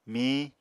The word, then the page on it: mihiñ: Apatani